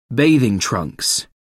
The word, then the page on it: bathing trunks: American English